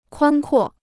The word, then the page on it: 宽阔: kuān kuò